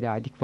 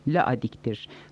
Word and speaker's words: Ladik: Denizli'de ladik var, bir de Samsun'da. Ne var ki doğrusu ladik değil, laadiktir. Samsun'daki en yakın telaffuz edilenidir. Laidakia olduğu için laadik diyorlar.